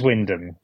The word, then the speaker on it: Wymondham: But near Norwich, there's a place spelled Wymondham. How are you pronouncing that? – Is it Wyndham or something? – It is Wyndham. – Yeah. But there's a lot of other letters in there that are just silent.